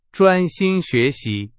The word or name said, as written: 专心